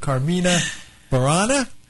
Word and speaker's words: Carmina Burana: And with us to tell us all about the world premiere of the Kansas City Ballet production of Carmina Burana.